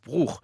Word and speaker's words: Bruch: Bruch, comme on prononce Ernst Bloch et comme on devrait prononcer Jean-Sébastien Bach.